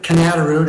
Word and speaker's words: Knatterud: So, Mr. Chairman, members of the committee, my name is Richard Cantarud. It is spelled K-N-A-T-T-E-R-U-D.